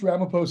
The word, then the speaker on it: Ramapo: I always say this wrong. – It's the East Ramapo Central School District.